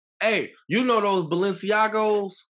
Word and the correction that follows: Balenciaga: He said that all wrong, but I feel you. – Say it right for me one time for the one time. – Balenciaga.